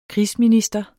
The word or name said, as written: krigsminister